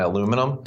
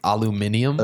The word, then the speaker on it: aluminium: I'm going to go to the Bottle Depot, I think they call it, and hang out with all my aluminum. It's pronounced aluminium. Uh, aluminium. Yeah.